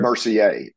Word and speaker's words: Mercier: So Laura Mercier, is that how you say it? Mercier? – Mercier, Laura Mercier.